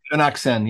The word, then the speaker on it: Shaunak Sen: How do you say it? Chanuk Sen? – Chanuk Sen, yeah.